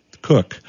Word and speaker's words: Koch: Cook. – Thank you very much. – K-O-C-H, but pronounced Cook.